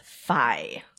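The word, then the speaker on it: fie: This one is fi. – Fi. – Spell it. – F-I-E. F-I-E. Like fee, fi, fo, fum? – That's correct.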